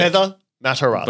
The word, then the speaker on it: Heather Matarazzo: Sorry, Heather Matarazzo. I said her name wrong. – You did. – Heather Matarazzo.